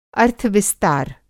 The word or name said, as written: अर्थविस्तार